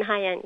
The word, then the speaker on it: Haiyan: So that would include something like the Typhoon, I don't know if I can pronounce it correctly, Haiyan. Yeah, Haiyan. – Typhoon Haiyan, yeah, that's right.